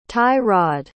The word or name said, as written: tie-rod